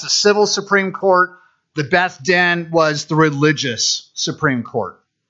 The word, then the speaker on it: Beth Din: Two words, B-E-T-H-D-I-N, the Beth Din. Am I saying that right?